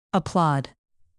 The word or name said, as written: applaud